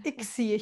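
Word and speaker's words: ICSI: Und dann gibt es auch noch die ITSI. Habe ich es richtig gesagt? Ist, äh, Ixi, ich glaube, man spricht Ixi. so wie Nix.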